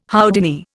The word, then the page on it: Houdini: Indian